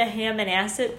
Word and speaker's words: Hammonasset: So I drove down to Hammonasset Beach.